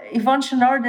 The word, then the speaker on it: Chouinard: So I think you pronounce it Yvon Chouinard, but I'm not sure.